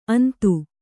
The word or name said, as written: ಅಂದು